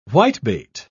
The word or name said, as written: whitebait